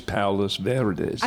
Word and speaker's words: Palos Verdes: Yes, that sounds very nice, Palos Verdes.